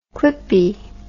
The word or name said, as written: clippie